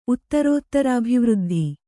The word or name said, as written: ಉತ್ತರೋತ್ತರಾಭಿವೃದ್ಧಿ